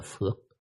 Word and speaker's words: phước: Phúc là cách đọc nguyên gốc hoặc âm nguyên của nó, nhưng phước là chỉ có ở miền Nam này, vào thì miền Trung tới đây miền Nam mới đọc thôi.